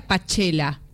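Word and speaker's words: Pajchela: Se escribe con J entre Pa y Che, pero se pronuncia Pachela.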